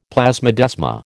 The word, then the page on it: plasmodesma: American English